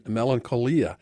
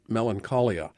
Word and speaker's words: Melancholia: We checked with the film's distributor and found that they prefer the pronunciation melancholia, not the anglicized melancholia.